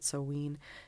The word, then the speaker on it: Samhain: So mine, Samhain, I should say, it's spelled Samhain. I always mispronounce that, but Samhain.